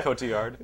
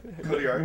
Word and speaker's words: Cotillard: I know it's Marianne Cotillard or however you say her name. but it's not even a hard name. – Yeah. – You say it. What? – I don't know. – Cotillard. – Cotillard.